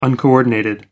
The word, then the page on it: uncoordinated: American English